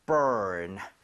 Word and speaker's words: burn: 얘도 발음할 때 r 살아있죠. – 예. – 그래서 아까 warn처럼 혀를 당기고 은 하고 아하. – finish 하셔야 돼요. 자, 해볼게요. burn, burn.